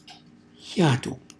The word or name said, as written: xjatu